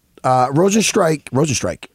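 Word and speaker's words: Rozenstruik: Rosenstrike, I say it right? – Yep.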